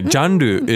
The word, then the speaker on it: ジャンル: ジャンル is genre, but it's a very... – ジャンル、そうです。和製語ですね。 – Not really. It's pronounced very differently from English. We say ジャンル。